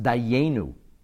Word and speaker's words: Dayenu: So since it's pronounced Dayenu as a tzere, as if it would have two Yuds, so therefore the Rebbe says that fits the teaching of the Zevach, of the Mishnah Schlitz nonetheless.